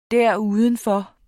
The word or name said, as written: derudenfor